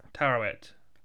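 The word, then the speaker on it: Taweret: The Hippopotamus's woman is the Egyptian goddess Tawet. Is that how you pronounce it? I can't remember. – Tawet.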